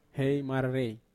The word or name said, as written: hei mararei